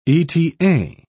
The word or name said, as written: eta